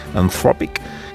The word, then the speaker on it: Anthropic: El Mundo destaca la presencia junto al Papa ayer de Christopher Ola, que es el cofundador de la tecnológica Anthropic, me ha dicho Marta que se dice así, Anthropic, que está embarcada esta compañía en la poco disimulada tarea de ser vista como la IA buena.